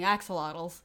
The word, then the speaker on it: axolotls: I think it's probably because I was, like, again, picturing them as being axolotls. I never know if I'm saying that word right. Is that how you say it?